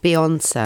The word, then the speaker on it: Beyonce: Beyonce.